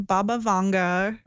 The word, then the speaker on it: Baba Vanga: So my prediction comes from Baba Vanga.